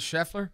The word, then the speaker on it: Scheffler: Say Scottie Schlereth three times fast. – Scottie Scheffler. – Oh, am I saying it wrong that way too? It's Scheffler? – Yeah.